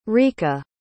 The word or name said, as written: rika